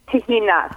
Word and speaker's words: tahini: And what's the other pronunciation for the word tahini? – Tahina. – Tahina. – Or tahina. – Okay. – You really want to get guttural in your throat.